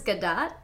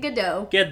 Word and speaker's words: Gadot: No, I was corrected recently, I was asked, did you know that her last name is Gadot? And I'm like, nope, definitely been pronouncing it Gadot.